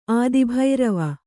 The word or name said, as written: ಆದಿಭೈರವ